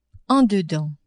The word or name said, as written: en dedans